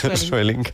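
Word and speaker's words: Sweelinck: Sueling de Ámsterdam, ¿lo he dicho bien? – Sueling. Muy bien, Sueling.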